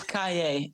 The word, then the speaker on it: Caillet: It's Caillet, like Yippie Caillet, motherfucker.